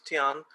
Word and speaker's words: Tian: Sorry if I mispronounced that. – No, you're right. It's Tian.